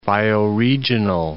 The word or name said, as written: bioregional